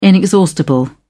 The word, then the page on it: inexhaustible: British English